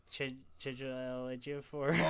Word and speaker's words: Chiwetel Ejiofor: Chiwetel Ejiofor. – I commonly get slagged on this podcast for not being able to pronounce names. I'm very proud that I can pronounce that right.